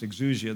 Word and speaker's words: exousia: It is for this reason the woman ought to have authority, the word is pronounced exousia in the Greek language, over her own head because of the angels.